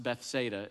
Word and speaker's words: Bethsaida: It's actually pronounced Bethsaida in Southern English, but he says Bethsaida because he's showing off.